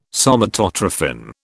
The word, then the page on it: Somatotrophin: British English